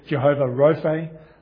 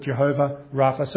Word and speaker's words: Jehovah Rapha: Sometimes you see it as Jehovah Rophe and different pronunciations there, but we'll stick with Jehovah Rapha.